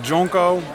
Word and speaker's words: DJ Onko: DJ Onko. – Oh ja, DJ Onko. Lekker misleidend. – Er zit geen DJ in de band. En DJ Onko spreek je uit als Jonko.